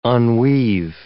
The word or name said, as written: unweave